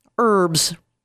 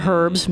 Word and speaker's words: herbs: So, herbs. Or herbs, depending on whether or not you pronounce them correctly.